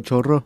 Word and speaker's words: chorro: Did I say that right? Diarrhea in Spanish is churro. – Yo, chorro. – Chorro. – Chorro. – El chorro. It sounds funny. Like Zorro, but like a like a shitty Zoro.